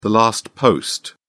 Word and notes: last post: British English

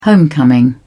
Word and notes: homecoming: British English

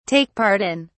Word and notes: take part in: British English

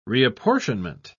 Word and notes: reapportionment: British English

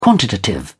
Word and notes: quantitative: British English

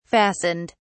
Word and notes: fastened: British English

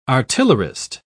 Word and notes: artillerist: British English

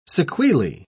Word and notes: sequelae: British English

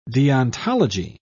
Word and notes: deontology: British English

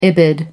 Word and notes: ibid.: American English

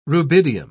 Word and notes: rubidium: British English

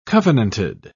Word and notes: covenanted: British English

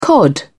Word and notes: cod: British English

